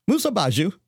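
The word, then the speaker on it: Musa basjoo: It's the Musa Baju. – Could you spell that? – Musa Baju. So it's Musa, M-U-S-A, and the Baju is B-A-S-J-O-O. Musa Baju, say it 3 times.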